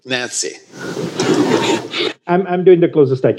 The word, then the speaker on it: Gyantse: Okay, but it's spelled Gyantse, and you pronounce it almost like the lady's name Nancy. – Yeah, with a G-Y in front of it.